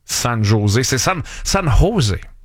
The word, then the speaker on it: San José: Puis un de nous deux, au lieu de dire San José, qui est la prononciation parfaite, un de nous deux aurait dit San José.